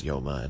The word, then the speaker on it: Yoma: Neither is Meseches Yuma the correct pronunciation. It's Yoma and so what.